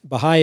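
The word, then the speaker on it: Bahá'í: WWBahai, that's B-A-H-A-I. Bahai is usually spelled with apostrophe I, Bahai.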